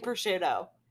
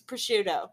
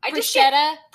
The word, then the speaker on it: prosciutto: Pronouncing prosciutto. It's prosciutto. – Prosciutto. – Prosciutta. Prosciutto.